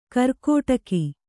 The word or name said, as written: ಕಾರ್ಕೋಟಕ